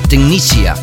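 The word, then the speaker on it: Technasia: La semana pasada tuvimos a Dead Mouse in the Mix y esta semana tendremos a Tecnacia, Tecnicia para pronunciarlo más correctamente, será nuestro invitado en los últimos 30 minutos de este show.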